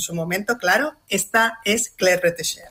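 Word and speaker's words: Claire Bretécher: Que nos dejó hace 6 añitos, en 2020, y menos mal que lo pronuncias tú bien, porque llevamos intentando pronunciarlo en la redacción nosotros todo el día y somos incapaces de pronunciar bien lo de Claire Retesher, pero bueno, ya está para decirlo bien.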